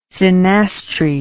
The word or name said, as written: synastry